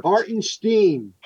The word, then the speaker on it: Hartenstein: And then, like he said, Hartstein? – He prefers the German pronunciation, which I got from one of the games. Hartenstein or... – Hartenstein. – Hartenstein, yes.